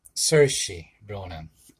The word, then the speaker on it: Saoirse Ronan: How do you pronounce her name again? Saoirse Ronan. Say it.